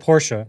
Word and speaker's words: Porsche: Notice I said Porsche. That's the right way to say it, even though I feel awful every time I say it.